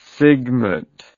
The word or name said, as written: sigmate